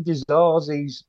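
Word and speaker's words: Disasi: Now, we've been getting Dizazi's pronunciation wrong, haven't we? It's what's it, the guy behind us? What did he say? – Dizazi. – Dizazi, yeah. You pronounce it Dizazi. – That would be the French way of saying it, actually.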